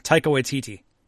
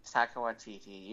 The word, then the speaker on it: Taika Waititi: so the other Star Wars news is uh, they have confirmed that Taka Wahi is doing. did I get his name wrong? – Taika Waititi. – Taika Waititi.